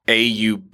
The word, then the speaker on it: Auburndale: And if you are looking for this, it would be spelled like AUBURNDALE. Mm-hmm. So Ruben says Ruben says Arbondale, yeah. But it's Auburndale.